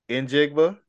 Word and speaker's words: Njigba: I say Jackson Smith, Ajigba. – Njigba. – Did I say that right? – Njigba.